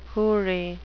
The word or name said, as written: houri